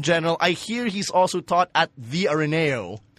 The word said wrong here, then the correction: Ateneo: Nee, yung pagkakonya, just Ateneo.